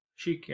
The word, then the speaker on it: Sheik: Sheik, yeah.